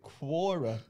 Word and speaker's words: Quora: So I've always been pronouncing it Quora. Like Quorn.